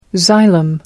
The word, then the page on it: xylem: American English